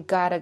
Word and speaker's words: gotta: 所以这个 T 就变成了一个 D 的音， I gotta go， I gotta go。